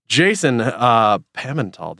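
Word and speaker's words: Jason Pimentel: Mr. Jason Pimental, did I get that right? – That was almost absolutely correct. Really close. – Good. – But it's like the font, it's a variable pronunciation.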